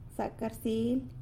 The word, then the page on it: Zac rsily: sanlucasquiavini